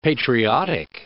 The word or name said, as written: patriotic